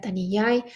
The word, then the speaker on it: Thaniyay: Si estás escuchando esto y necesitas ayuda, me puedes escribir a mí o puedes escribir a Taniyay. Se escribe T-H-A-N-I-Y-A-Y, -Y, igual Ahí en la descripción está el nombre.